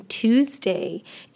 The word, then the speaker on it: Tuesday: One thing I want you guys to bear in mind is that the S in Tuesday is actually pronounced with a Z sound, Z, Tuesday, Tuesday. And the U in this instance is pronounced like an ooh sound, okay?